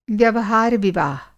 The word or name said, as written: व्यवहार-विवाह